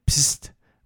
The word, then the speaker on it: Psst: along with the budget brand Pst, which I will insist that that's how it's pronounced, but P-S-S-T is how it looks on packaging, for paper products, and several other brands there.